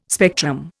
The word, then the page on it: spectrum: Indian